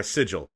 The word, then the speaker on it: sigil: If you find a sigil. Or a sigil. Sorry, I don't know. I always pronounce the G hard.